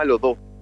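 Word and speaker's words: Lohtoh: Mira, el proyecto se llama Lodo. Escribe L-O-H-T-O-H y el acento oficial lo tiene en la segunda sílaba, por eso.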